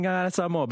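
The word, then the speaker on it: Gagana Samoa: So, Auntie, we thought we'd actually pull out Mato Peni Ma'api this morning and learn some choice words and expressions in Ngangana Samoa. But firstly, did I say that correctly? – Yeah, you did.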